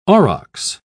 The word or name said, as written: aurochs